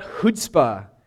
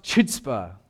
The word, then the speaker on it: chutzpah: If you were here 2 weeks ago, we talked about chutzpah, which I mispronounced chutzpah. That's kind of funny. You can laugh. Mostly because I'm not Jewish. I mispronounce it.